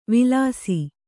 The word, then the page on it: ವಿಲಾಸಿ: Kannada